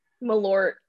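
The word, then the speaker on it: Malört: Malort.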